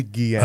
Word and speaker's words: Guillén: Uh, Harvey Guillén. I, I, never pronounce his name right. There we go. Harvey Guillén.